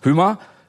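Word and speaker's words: Hymer: Denn beim Wohnmobil- und Wohnwagenbauer Hümer, spricht man das Hümer aus, Hümer, von dem Jacqueline zur Lufthansa kam, hat sie ihren Job auf ganz besondere Weise ergattert.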